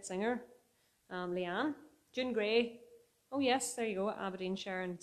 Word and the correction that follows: Aberdeenshire: Scotland. I'm glad I said that right. Aberdeenshire.